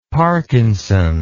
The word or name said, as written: Parkinson